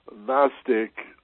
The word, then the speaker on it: Gnostic: Yeah, Jacob, I think it's simply called the Gnostic Gospels, but remember, Gnostic has a silent G on the front, just like Gnostic means knowledge, and the word knowledge has a silent K on the front. So that's sort of why the Greek version of Gnostic starts with a silent letter also, the silent G. So Gnostic is G-N-O-S-T-I-C.